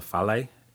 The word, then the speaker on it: fale: And a house might be called a fale, F-A-L-E, whereas in Maori be a fare, W-H-A-R-E. And so there's clear similarity among the languages. But anyway, Samoans say it that way, and that's how I pronounce it.